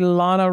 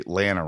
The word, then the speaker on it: Lana: Estevan G, DJ Kento, Garrett W, Kimberly L, Justin S, Tom N, Tony V, Rodolfo, Jason W, Osama A, Lana Rhymes with Banana W, Nancy D, Brian G, Sarah W, Justin T, and Lucy O. – Actually, Lana rhymes with banana.